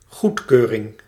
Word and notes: agrément: France; [a.ɡʁe.mɑ̃]

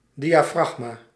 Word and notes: diafragma: /dijaːˈfrɑxmaː/

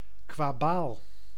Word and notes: lote: France (Île-de-France); /lɔt/

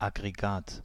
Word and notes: agrégat: [a.ɡʁe.ɡa]

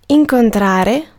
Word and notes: incontrare: /iŋ.kon.'tra.re/

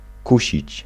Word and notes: verleiden: /vərˈlɛidə(n)/

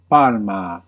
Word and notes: date: /deɪt/

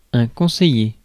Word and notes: conseiller: France; /kɔ̃.se.je/

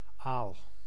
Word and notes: anguille: France

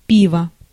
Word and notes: пиво: [ˈpʲivə]

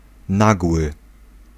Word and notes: soudain: [su.dɛ̃]; France

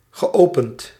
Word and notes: geopend: /ɣǝːɔʊpənːt/

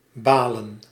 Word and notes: râler: France; /ʁɑ.le/

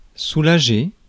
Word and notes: soulager: France; [su.la.ʒe]

